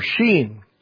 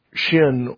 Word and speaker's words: Shin: It's the Hebrew letter Sheen or Sin. Some people will pronounce it Shin or Sin, but Sin, I don't, because it, as you'll see, it has two ways that it, that letter is used.